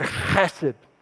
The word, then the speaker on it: chesed: If you're not spitting, you're not saying it right. It is a Hebrew word, chesed.